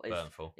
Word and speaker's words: Bernthal: For a little bit of comic book reference, John Bernthal who played, is it John Bernthal, Bernthal? – Bernthal. Yeah, he's like the can't pronounce his last name.